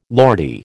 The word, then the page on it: Lordy: American English